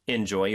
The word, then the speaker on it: enjoy: Enjoy your flight. – 빈칸에는 Enjoy, capital E-N-J-O-Y. – 네, 발음이 중요하죠. Enjoy가 아니고 enjoy 이 음절의 강세가 있습니다.